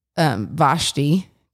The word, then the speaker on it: Vashti: But like looking at the Vashti, I said it right. Yes.